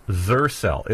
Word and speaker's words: Xercel: All right, so the next guess, what is Zercel? It's spelled X-E-R-C-E-L, but it's pronounced like Xerxes, like the ancient king, the Persian king here.